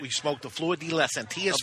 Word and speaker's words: Flor de las Antillas: Flor de las Antillas. You want the A to Antillas. – Antillas. – Flor de las Antillas. – Flor de las Antillas.